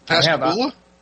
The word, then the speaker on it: Pascagoula: Pascagoula? – Yeah, that's it.